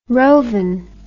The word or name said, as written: roven